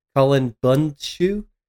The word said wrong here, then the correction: Bundschu: That's Bundschu.